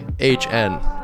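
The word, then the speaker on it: Ihsahn: Okay, the decent album I had this week was by Ishan, which is not spelled how it's phonetically pronounced. Which I looked up, it is Ishan, but it is Ishahn. And his album is Amr, A-M-R.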